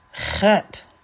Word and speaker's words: Chet: Chet. This is a different sound for those of us who are English speakers, but it is pronounced ch, as in Bach.